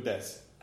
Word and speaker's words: desu: Right, des, and it's D-E-S-U, but you don't pronounce the U.